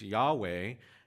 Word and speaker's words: Yahweh: He gives us his name in the Hebrew pronunciation. It's Yahweh, and packed into that name is the covenant-keeping God.